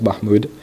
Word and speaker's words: Mahmoud: My name is Saif, S-A-I-F. My last name is Mahmoud, M-A-H-M-O-U-D.